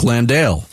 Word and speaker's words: Landale: What Bick is referring to is a lot of people put the stress on the wrong syllable. Yeah. Jock Landale.